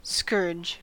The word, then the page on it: scourge: American English